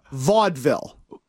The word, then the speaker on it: vaudeville: Okay, it's pronounced Vaudeville.